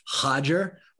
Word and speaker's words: Roger: There are a lot of Gracies, and they all start with the name or the letter R almost, and none of them are pronounced like Roger. It's Hodger or Hoyce.